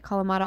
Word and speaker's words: Kalamata: Kalamata olives? – Yeah.